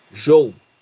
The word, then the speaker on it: Zhou: Eu, eu, eu fui ver a pronúncia correta do Zou. Você sabe como se fala Z, do Z e H em chinês? – Como? Eu, Zhou. Guan Yu Zhou. Exatamente.